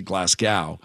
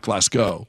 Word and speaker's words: Glasgow: And yes, I believe you pronounce it Glasgow, not Glasgow, but it's spelled the same.